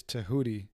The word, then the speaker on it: Tehuti: You know, but it's like T-H-O-T-H, not T-O-T-H. – Yeah, you know, it's wild. The way you're actually supposed to pronounce Thoth or Thoth is Tehuti. – Really? – Yeah, it's Tehuti.